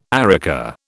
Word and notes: areca: British English

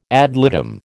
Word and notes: ad litem: American English

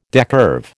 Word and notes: decurve: American English

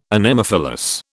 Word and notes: Anemophilous: British English

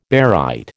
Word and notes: barite: American English

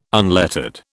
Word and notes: unlettered: British English

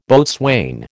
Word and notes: boatswain: American English

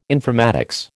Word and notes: informatics: American English

